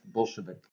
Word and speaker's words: Bolshevik: Now I guess I've always heard it pronounced Bolshevik.